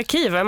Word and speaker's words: Kiev: Är det Kiev? Vilket välkomnande. Det är Kiev. Man uttalar det som man uttalade det tidigare.